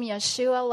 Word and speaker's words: Yeshua: It doesn't matter if you pronounce His name Yeshua like they do in Israel, or you pronounce it Jesus as we do here, or Jesus as they do in Spanish-speaking nations, or the other ways that His name is pronounced across our globe, our planet.